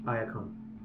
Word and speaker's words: Iacon: Iacon. – Iacon? – Yeah, Iacon. – How do you spell it? – I-A-C-O-N.